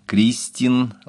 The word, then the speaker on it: Кристин: Во всех норвежских именах собственных ударение падает на первый слог. Кристин, Лавранс, Ивар, Еслинг, Сюндбю, Рангфрид и так далее.